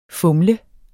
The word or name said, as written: FUMLEDES